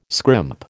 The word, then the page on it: scrimp: American English